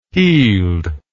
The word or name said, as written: heeled